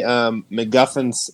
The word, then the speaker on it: MacGuffin's: So I think they're trying to say MacGuffin's Scepter.